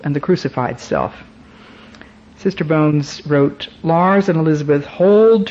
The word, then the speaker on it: Bowen: The last name is B-O-W-E-N, but they pronounce it Bones.